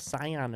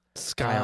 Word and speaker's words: Scion: What you got going on there with that little Scion of yours? – Scion, I think it's pronounced.